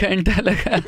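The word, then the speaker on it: Kaanta Laga: He's like, I'm like, kanta laga. – This sounds like I'm saying it wrong. – No, you're saying it perfectly.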